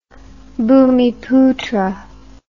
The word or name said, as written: bumiputraization